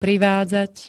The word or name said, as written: privádzať